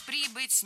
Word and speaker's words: прибыть: Прибыть неверно, правильно прибыть. И, кстати, перед нами слово с подвижным ударением. Ударение кидает из стороны в сторону, но в проблемных формах оно остается на приставке.